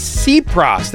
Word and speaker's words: Tsipras: It's Tsipras. That's the Prime Minister. Alexis Tsipras. It's a it's your typical silent T in the Greek language at the beginning of names.